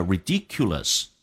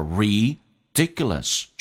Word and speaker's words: ridiculous: 我们来看所有听力跟口语的秘诀。今天的主题叫做 This is ridiculous. 这太荒唐了。第一句话 This is ridiculous. I can't believe you've been sleeping with someone else. 第一个单词 ridiculous 指的是荒唐的。很多同学把这个单词的重音放在了 C 上，变成了 ridiculous. No, 叫做 ridiculous, 重音在 D 上。This 跟 S 有一个零叫 this is, 重音在 D 上，同时后面的 K,在音标当中弱化成一个个，这个发音叫 ridiculous.